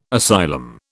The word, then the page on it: asylum: British English